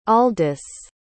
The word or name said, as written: aldis